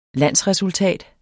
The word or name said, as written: landsresultat